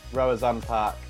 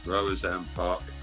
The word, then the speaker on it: Roazhon Park: Roazhon Park. – Roazhon Park.